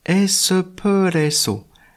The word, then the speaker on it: espresso: Un expresso se prononce espresso, espresso, qui vient donc de l'italien espresso. Ce n'est donc pas un X mais un S, espresso.